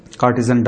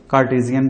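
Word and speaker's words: Cartesian: کارٹیزن doubt, کارٹیزین doubt میرا خیال بنے گا صحیح تلفظ اس کا, کارٹیزین ڈاؤٹ, یہی شک ڈیکارٹی, ڈیکارٹ کا جو شک ہے.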